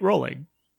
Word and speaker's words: Rowling: Someone said it rhymes with bowling, right? Rowling.